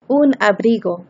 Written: un abrigo

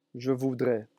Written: je voudrais

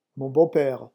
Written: mon beau pere

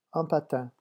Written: un patin

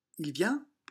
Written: il vient

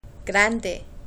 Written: grande

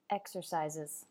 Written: exercises

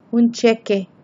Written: un cheque

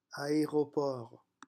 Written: aeroport

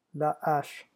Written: la hache

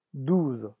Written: douze

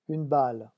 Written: une balle

